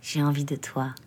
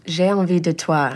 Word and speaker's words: j'ai envie de toi: Yeah, yeah, this is longer, like, j'ai envie de toi. – J'ai envie de toi. – Yeah. – Everyone say it. J'ai envie de toi. – You say it correctly.